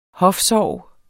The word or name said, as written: hofsorg